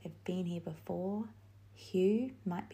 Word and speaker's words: Hugh: So the next name is Hugh, H-U-G-H. And it is a simple one-syllable name, but with a huge meaning.